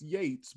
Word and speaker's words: Yeates: It's pronounced Yeates, but it's spelled Y-E-A-T-E-S.